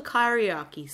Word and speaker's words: kyriarchy: This is all called the kyriarchy. Say it with me, kyriarchy. – Kyriarchy. – K y r i a r c h y. Kyriarchy. It's the Kyriarchy.